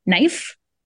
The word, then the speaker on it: knife: So we have all of this English spelling where another example I really like is um, knife. – So right. knife, we have silent K in the beginning of it.